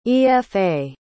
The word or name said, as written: efa